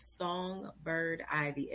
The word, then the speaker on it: Songbird Ivy: So everything is Songbird Ivy, S-O-N-G-B-I-R-D-I-V-Y.